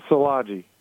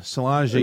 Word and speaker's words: Szilagyi: Yeah, Szilagyi. – Oh, that's so much easier than it's spelled. Szilagyi.